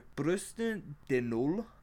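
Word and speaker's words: bûche de Noël: Then the fourth food is the Yule log cake, or Brusten de Nul, which is spelled B-U-C-H-E space D-E space N-O-E-L, which is a symbolic depiction of the Yule log, which I mentioned earlier.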